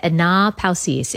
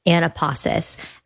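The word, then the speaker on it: anapausis (ἀνάπαυσις): It's a little bit strange how you say it, anapausis. If you read it like in our English dialect, we'd probably say anapausis.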